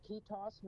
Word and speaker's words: ketos: I don't know how you pronounce it, but it's K-E-T-O-S, ketos. Maybe you probably yeah. – better. – That's how I've always heard it, ketos.